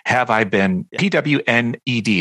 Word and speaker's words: Have I Been Pwned: but it's Have I Been, PWNED. It's basically pronounced Have I Been Pwned.